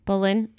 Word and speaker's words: Boleyn: but it is Anne Boleyn? Boleyn? Is that how you pronounce her name? Last name? – Boleyn.